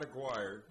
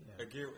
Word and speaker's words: Aguirre: Yeah, it's not Aguirre. – Okay. – Aguirre, like war.